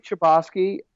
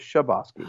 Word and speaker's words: Chbosky: If people say Chbosky, I'm cool with that. I'm also cool with Chbosky. – Oh, Either is fine. – What do you say? Because that's what's important. – Chbosky.